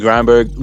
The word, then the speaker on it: Grindberg: The story behind it is originally my name is pronounced Grindberg. – So yeah, that is pronounced Grindberg. – That's like Grindberg. – How the family pronounces it. Technically it's pronounced Grindberg, but since you know, doing music and stuff, people started calling me Grindberg because they read my name and they assume it's Grindberg, but I can't fault them for that.